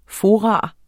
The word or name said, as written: FORAENES